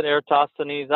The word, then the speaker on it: Eratosthenes: Is that how you pronounce it, Eratosthenes? I always, yes. – You know, it's hard. These Greek words, read all the time, you don't know how people pronounce them until you talk to people. – Well, I've never been corrected, so that's how I say it.